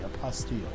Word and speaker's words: apostille: However, through the Hague Compact, you can have what's called a stamp on it, a Hague apostille, I think that's the right pronunciation. It's apostille. I-L-L-E. T-I-L-L-E. A-P- O-S-T-I-L-L-E.